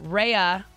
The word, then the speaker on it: Raya: Raya is how it's pronounced, I found out. I just found that out.